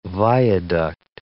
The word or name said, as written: viaduct